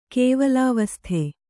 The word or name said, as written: ಕೇವಲಾವಸ್ಥೆ